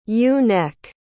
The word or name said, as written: ewe-neck